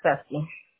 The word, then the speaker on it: Cecy: No, it's Ceci. – Ceci. How do you spell it? C-E-C-Y or with an I? – With a Y.